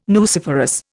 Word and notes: nuciferous: Indian

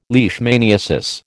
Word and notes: leishmaniasis: American English

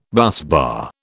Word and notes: busbar: British English

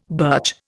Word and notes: birch: Indian

